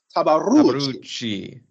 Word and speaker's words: Tabarucci: I mean, you know, just on film, you're seeing a little bit of what we saw from Carson Tabarachi, who we at the P. – No, you got to say it right. – We at the P, we know him as Carson Tabarucci.